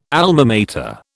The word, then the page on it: Alma Mater: British English